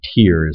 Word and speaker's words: Tyre: Sidon and Tyre, and I say Tyre, it's, I guess actually Tyre is the correct pronunciation. T-E-E-R-E would be the correct.